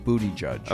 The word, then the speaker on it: Buttigieg: By the way, I don't mean to correct your pronunciation, but I believe it's pronounced booty judge.